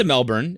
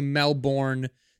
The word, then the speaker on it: Melbourne: If you say Melbourne, they get mad. Melbourne. – Melbourne. – I get it. I understand we're supposed to say it the right way, but it's spelled Melbourne, so... Melbourne, but I can't say Melbourne because it's not spelled M-E-L-B-I-N.